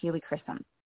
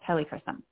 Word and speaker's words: helichrysum: And we have gotten such good results consistently with this essential oil, and it's called helichrysum, or you can be, it can also be pronounced helichrysum.